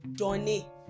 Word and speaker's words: donner: Let's take another ER verb, donner. Donner. Donner means to give. It is spelled D-O-N-N-E-R. D-O-N-N-E-R.